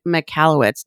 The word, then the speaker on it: Michalowicz: And today I have a special treat for you, Mike Michalowicz.